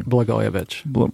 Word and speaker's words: Blagojevich: and Blagojevich, or I can never pronounce his name. – Blagojevich.